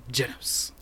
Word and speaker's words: Genos: Don't, you have to say his name that way, Genos.